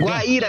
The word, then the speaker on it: Guaíra: Ô, é Guaíra. – Guaíra. – É Guaíra. – Ah, Guaíra, tá. Foi mal, foi mal. Eu falei errado aqui.